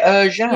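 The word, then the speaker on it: Ercan: Oh, so you say it, Erjan. – Yeah. So in typically in Turkish, C's are pronounced with a J.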